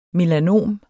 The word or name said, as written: melanom